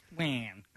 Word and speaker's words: Wan: Wan. – Am I saying it correctly? – No, you're right.